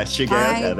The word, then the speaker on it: Shigeoka: Yeah, I put the gay in Shigeoka. Yeah, Shigeoka.